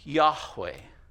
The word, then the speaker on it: Yahweh: And we've done our best to put that in something that we can pronounce, and so we say it's Yahweh, Yahweh.